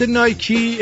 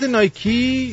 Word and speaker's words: نایکی: نایکی اعلام کرده به دلیل تحریم‌های آمریکا کفش به بازیکنهای ملی پوش ایران نمیده خیلی هم آلو دشواری نداره شرکت نایکی یا چنان که در ایران عموما تلفظ میشه نایک